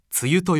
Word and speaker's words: 梅雨: 梅の雨と書いて、梅雨と読みます。